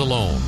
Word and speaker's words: Alon: Alone is alone. Now he's on the mound, got a few guys around him. – Okay. – Name spelled A-L-O-N and pronounced alone.